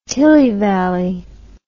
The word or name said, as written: tillyvally